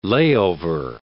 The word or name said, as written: layover